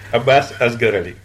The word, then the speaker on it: Abasse Asgaraly: Abasse Asgaraly.